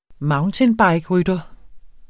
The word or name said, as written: mountainbikerytter